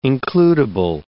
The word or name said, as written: includible